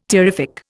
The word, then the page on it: terrific: Indian